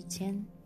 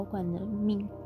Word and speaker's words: 菅: 我不知道为什么尖芒花的尖、草菅人命的尖，正确读音都是尖，而这个字在闽南语、客语、粤语中的发音。都更接近“管”，子音是 “k”，好像连日文、韩文里也有这个字。训读的发音也接近“ 管”。其实草间人命也常常听人误读为草草管人命，民间口语只有它发展的故事，错了纠正就好。